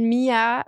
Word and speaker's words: Miha: Und eigentlich schreibt man Mia mit einem H in der Mitte. Das, äh, das schreiben die Leute auf Instagram manchmal falsch. Es ist nicht M-I-A, sondern also M-I-H-A.